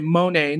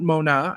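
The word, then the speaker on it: Mone: She's got this, by the way, Monet, not Mona. I know it's hard for some people. I don't get it. It's Monet Chiba. It's pretty easy to pronounce.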